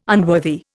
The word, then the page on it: unworthy: Indian